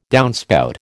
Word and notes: downspout: American English

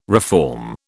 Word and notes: reform: British English

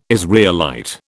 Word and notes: Israelite: British English

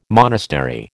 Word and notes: monastery: American English

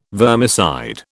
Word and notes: vermicide: British English

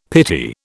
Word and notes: pity: British English

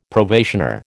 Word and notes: probationer: American English

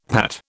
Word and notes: Pat: British English

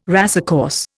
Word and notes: racecourse: Indian

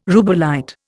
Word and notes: rubellite: Indian

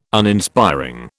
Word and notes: Uninspiring: British English

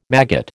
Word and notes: maggot: American English

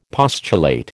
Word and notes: postulate: American English

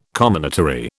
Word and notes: comminatory: British English

